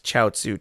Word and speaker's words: Chiaotzu: Toriyama uses kanji to connect Chiaotzu to Chinese culture. However, in kanji, the name is pronounced gyoza, like dumplings that you'd order at a ramen restaurant.